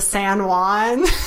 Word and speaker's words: San Juan: And the song they sang about it was like, you spoiled my trip to San Juan. Because I wore white pants and I... – To rhyme with tampon? – Yeah.